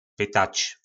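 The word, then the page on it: Pytac: nářečí Po našimu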